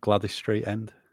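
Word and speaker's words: Gwladys Street: Is that how you pronounce it? Gladys? I don't know. It's got a W in it, isn't it? I've never noticed that before. – Yeah, Gladys Street End.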